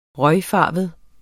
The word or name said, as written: røgfarvet